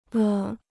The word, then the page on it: 了: le